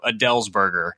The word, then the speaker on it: Edelsberger: It's actually pronounced closer to Edelsberger, as in like the singer Adele is eating a hamburger. So Edelsberger.